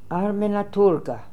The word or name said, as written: Armanatoorga